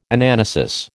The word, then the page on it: Anamnesis: American English